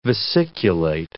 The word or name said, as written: vesiculate